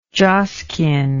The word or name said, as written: joskin